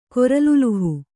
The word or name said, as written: ಕೊರಲುಲುಹು